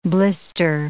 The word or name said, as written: blister